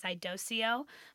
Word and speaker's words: iDoceo: It is spelled I-D-O-C-E-O. I've also heard it referred to as Idoceo, but I'm pretty sure it's supposed to be Idoceo.